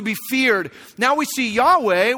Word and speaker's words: Yahweh: We pronounce it in English as Yahweh.